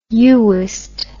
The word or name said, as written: uwist